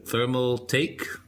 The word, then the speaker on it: Thermaltake: Thermal Take.